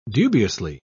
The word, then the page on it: dubiously: British English